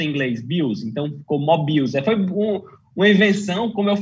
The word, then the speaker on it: Mobills: Então, a minha pronúncia era incorreta, eu botava a sílaba tônica no final, Mobills, não é? É Mobills, não é isso? – É Mobills, porque Mobills, Mobills, curiosamente, foi uma...